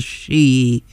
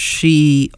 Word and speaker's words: Sidhe: Before that was the Waterboys with The Hosting of the She. Sometimes you'll see it spelled S-I-D-H-E, but it is pronounced She, always means the fairy folk, from their album An Appointment with Mr. Yeats.